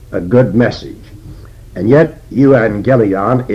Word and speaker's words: euangelion: But in Greek, when two G's come together, the first G actually becomes an N, and we say euangelion. EU is a prefix. And this prefix means good. A-N-G-E-L, that's actually the word angel.